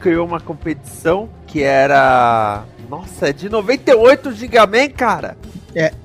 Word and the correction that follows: Gingaman: Gingaman, Gingaman.